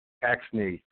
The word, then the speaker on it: Axne: Is it Axne? – Axne.